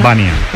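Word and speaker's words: Janža: Sí, sí, es esloveno, es Bania, pero la Bania, J, Bania. La J como la Jota, sí. – Bania.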